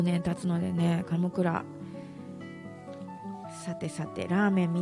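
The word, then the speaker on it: 神座: 私はあの先週も言いましたが、カムクラ好きですね。最初はね大阪だけだったですけどね、今は東京にもありますけど、神に座ると書いてカムクラ、白菜がいっぱい入ってるやつね、すごい大好きです。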